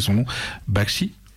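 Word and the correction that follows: Bakhshi: c'est ça ? – Barchi. – Barchi, voilà, je savais que je l'écorcherais, donc désolé.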